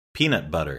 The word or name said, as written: peanut butter